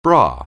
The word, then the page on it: bra: anglais